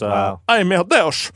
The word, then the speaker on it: Ai, meu Deus: Oh, my God, in Portuguese with the Portuguese accent is, "Ai, meu Deus."